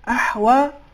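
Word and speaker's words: أحوى: بعض دفعہ کچھ افراد اس کو بھی استعلاء کے ساتھ ادا کرتے ہیں یعنی اس کو بھی موٹا کر کے تلفظ کرتے ہیں اس میں بھی تفخیم ادا ہوتی ہے یہ نہیں ہونی چاہیے چونکہ جو سات حروف استعلاء کے بیان ہوئے اس میں حرف ہا نہیں ہے لہذا اس کا صحیح تلفظ احوا اور یحییٰ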